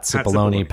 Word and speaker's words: Cipollone: Because it's Pat Cipollini is how it's pronounced. Pat Cipollini. Pat Cipollone, Patsy Bologna, Patsy Bologna.